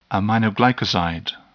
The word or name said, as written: aminoglycoside